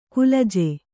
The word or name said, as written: ಕುಲಜ